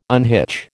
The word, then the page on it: unhitch: American English